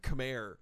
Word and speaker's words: Khmer: Khmer. – K-H-M-E-R? – Yeah. – Khmer? – Khmer. – Where's the A? – That's just Khmer. How it's pronounced. – So the H makes an A sound? – It does.